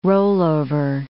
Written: rollover

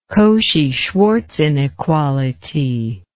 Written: Cauchy-Schwarz inequality

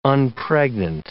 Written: unpregnant

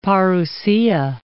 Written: Parousia

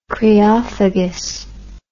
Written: creophagy

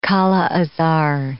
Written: kala-azar

